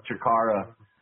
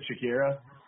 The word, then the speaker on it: Chikara: When you mention jack-o'-lantern, it flashes me back to when I mispronounced Chikara at a show and Chikara? – Got booed. I said Shakira.